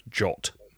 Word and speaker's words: JWT: This is, I think it's pronounced JOT. Even though it's written JWT, this is JSON Web Tokens.